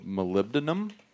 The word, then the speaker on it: molybdenum: I mean, and what people should know is that that mountain is full of molybdenum. Did I say that right? – You nailed it.